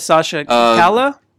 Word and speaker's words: Calle: Yes, Sasha Calle. – Calle sounds like L-E.